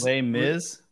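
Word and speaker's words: Les Misérables: And so he asks about the play, the Les Miserables. I can't remember pronounce that right, but that play. And so Lambda, this is Lambda's. – Les Miserables.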